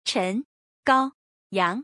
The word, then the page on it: 陈高阳: Chen Gaoyang; Slow